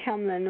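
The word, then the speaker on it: Tamblyn: Oh, and I don't know Will or what so, but I guess Amber Tamlin, I'm saying that right, Tamlin, right? – Yes.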